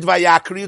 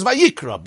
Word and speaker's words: Vayikra: But if you read it Vayakri, it's wrong. Because the pronunciation is Vayikra.